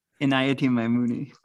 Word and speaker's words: Inayati Maimuni: As a Zaikir with the Inayati Maya Muni Order, did I say that right? – Inayati Maimuni, close.